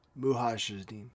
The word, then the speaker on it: Mujahideen: But yeah, the Mujahideen, I can't pronounce it. Dumb American. – Mujahideen.